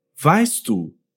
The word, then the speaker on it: weißt du: Und ein Tipp für die Aussprache, wir müssen nicht weißt du so getrennt aussprechen. Der T und D machen eigentlich nur einen einzigen Klang.